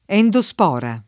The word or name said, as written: endospora